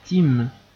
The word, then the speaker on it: Tim: Mon prénom est Tim.